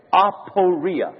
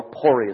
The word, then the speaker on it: aporia: You are stuck in an aporia, or aporia is another way to pronounce it. A-P-O-R-I-A.